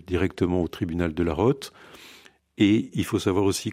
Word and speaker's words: Rote: Pourquoi un dossier nullité est transmis à la ROTE du Vatican? La ROTE, je ne sais pas, excusez-moi, je ne sais pas comment on prononce. – Alors on dit la ROTE.